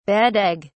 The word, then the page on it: bad egg: British English